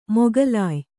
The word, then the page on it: ಮೊಗಲಾಯಿ: mogalāyi